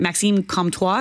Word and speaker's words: Maxime Comtois: is it Maxime Comtois? Am I saying that right? – You are.